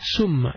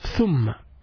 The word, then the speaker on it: ثم: ثم اتخذتم العجلة ثم then after that ثم then it's not ثم it's ثم you pronounce it a little bit taking your tongue out ث ث ثم ثم means then ثاء ميم and a تشديد on top of the ميم so we say ثم ثم